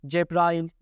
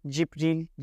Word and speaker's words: Cebrail: Cebrail ismi mesela bazı okumalarda tam 7 farklı şekilde telaffuz ediliyor. Cebrail, Cibril, Cibrin gibi hepsi aynı manaya gelecek farklı lehçelerde okumalardır.